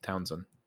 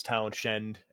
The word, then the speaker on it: Townshend: Spelled Townshend, but it's pronounced Townsend. – I think at the hospital, you could say to a nurse or a doctor, you know, is this Townshend?